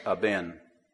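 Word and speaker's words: aben (אבן): In the Hebrew, the word stone is the word aben. It's spelled with the Hebrew letters Aleph, Bet, Nun.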